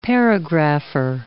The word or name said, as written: paragrapher